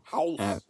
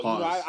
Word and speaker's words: Haas: House. – Be like a dick. Is it House or is it Haas? – Oh, Haas. – I don't even know. – Rhymes with pause.